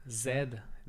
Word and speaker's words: Zed: Zed, that's how you pronounce it.